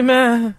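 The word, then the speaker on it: Määttä: It's you got to get scared in the middle of the last name to make that work. Mete. – Oli Mete.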